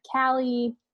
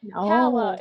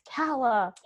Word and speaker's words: Calla: But the rest of the family was calling her Callie, Cal. – Oh, yeah. And you're like, no. Calla. It's Calla. – Calla.